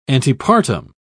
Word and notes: antepartum: British English

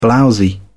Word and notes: blowsy: British English

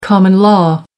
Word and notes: common law: American English

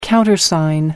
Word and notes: countersign: American English

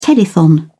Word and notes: telethon: British English

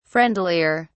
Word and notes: friendlier: British English

